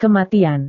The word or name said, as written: kematian